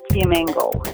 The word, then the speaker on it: Fiamengo: It sounds like flamingo, but Fiamengo is the name, yeah.